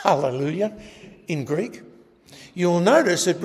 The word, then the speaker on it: Hallelujah: That's called a rough breathing, and it means when you pronounce that word, you start with a H. Hallelujah.